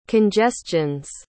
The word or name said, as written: congestions